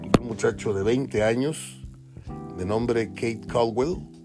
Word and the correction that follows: Cowell: otra vez Kate Cowell, lo dije mal al principio, no lleva D anterior a, la W, es Kate Cowell o Cowell.